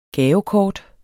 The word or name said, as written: GAVEKORTETS